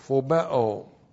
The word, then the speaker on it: phobeo: P-H-O-B-E, long O, phobeo.